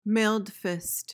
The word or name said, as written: mailed fist